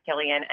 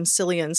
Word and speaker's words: Cillian: It looks like Killian, so that might have been part of the confusion, but it is Killian, and people were curious about how to say his name.